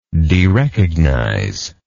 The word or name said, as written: derecognize